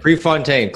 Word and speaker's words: Prefontaine: Prefontaine, close enough.